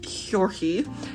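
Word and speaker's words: György: It looks like George, but I think it's pronounced Hjorki, being from Exed line, and her mother, Anna, was from the Simoleon line.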